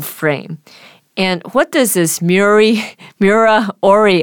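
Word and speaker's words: Miura-ori: Miura Ori. – Okay. Miura Ori, invented by Japanese astrophysicist Koryo Miura in 1970.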